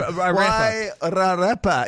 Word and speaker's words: Wairarapa: It's hard with the critic pronunciation. Wairarapa. Yeah, there you go.